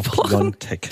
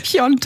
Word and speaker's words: Piontek: Habe ich es richtig ausgesprochen? – Genau, Piontek. – Piontek.